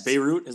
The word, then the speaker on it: Beirut: Beirut, is that how you pronounce that? – Sure.